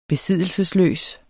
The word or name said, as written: besiddelse